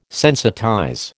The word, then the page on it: sensitize: American English